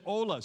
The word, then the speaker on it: hola: Well, there's an H there. You don't say hola. You say hola. So the H is silent.